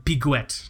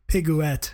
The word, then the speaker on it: Piguet: it's pronounced Adhemar Piguet, I believe, right? – Piguet. – Piguet.